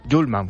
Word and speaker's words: Hjulmand: Quien le ha presentado lo ha pronunciado así, Hjulmand. – Julman. Bueno, lo consideramos y a partir de ahora lo llamamos así.